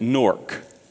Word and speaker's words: Newark: Do you pronounce that city's name Newark? Everybody raise your hand if you pronounce it Newark. How many of you pronounce it Newark? Okay, you're wrong. It's Newark.